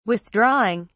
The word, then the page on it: withdrawing: British English